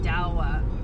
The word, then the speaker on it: Douwe: Because the name of the group is D-O-U-W-E, and then Bob. D-O-U-W-E. Like Dewey? – Dawa. – Dawa Bob? Sure.